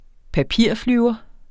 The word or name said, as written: papirflyver